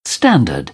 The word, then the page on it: standard: British English